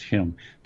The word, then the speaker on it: Home: And this is his son, Home, the British pronunciation is Hume, Nathaniel Hume.